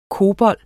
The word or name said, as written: cobol